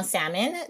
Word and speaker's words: Salmon: So my name is Danielle Salmon. That's S-A-L-M-O-N, salmon like the fish. L is silent.